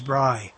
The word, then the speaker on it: Brei: A-L-L, no, A-L-O-N-N-A, A-L-O-N-N-A-B-R-E-I. That's a Bri-ish. Bri-ish. Bri. That's B-R- Bri, B-R-E-I, in German is Bri.